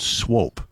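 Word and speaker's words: Swoope: It's going to be here in the Shenandoah Valley in a town called Swope. Yes, kids, if you're listening to us in Sacramento, we have a town called, it looks like it's spelled Swoop, but who would name a town Swoop?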